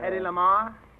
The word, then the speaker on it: Hedy Lamarr: One like Hedy Lala, Hedy La. – Hedy Lamarr? – How do you spell it? – H-E-D-Y-L-A-M-A-R-R-R-R-R-R-R-R. – That's it.